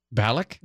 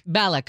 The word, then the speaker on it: Bialik: Balak. – Bialik.